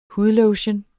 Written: hudlotion

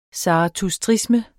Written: zarathustrisme

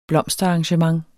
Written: blomsterarrangement

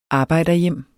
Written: arbejderhjem

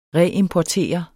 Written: reimportere

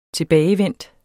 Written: tilbagevendt

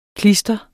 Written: klister

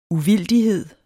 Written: uvildighed

